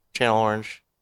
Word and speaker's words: Channel Orange: And we bought Chanel Orange. Channel Orange. I always say it wrong.